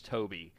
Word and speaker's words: Tobe: but he it's spelled T O B E Hooper, but it's Toby.